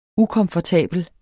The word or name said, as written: ukomfortabel